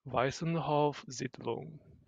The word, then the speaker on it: Weissenhof Siedlung: And that drawing was the floor plan of uh, Weissenhof Siedlung. Mm-hmm. Did mm. I do I pronounce it correctly? – Um, yeah.